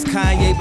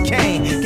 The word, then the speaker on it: Kanye: Now all I need is y'all to pronounce my name. It's Kanye, but some of my plastic still say Kane.